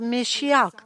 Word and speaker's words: Mashiach: E Gesù era pastore, profeta, e quindi questa parola Mashach, che in ebreo si pronuncia Mashiach, vuol dire colui che è stato mandato, colui che è stato unto.